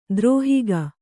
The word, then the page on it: ದುರುಹಿಕೆ: duruhike